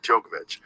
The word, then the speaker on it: Djokovic: Yeah, Djokovic.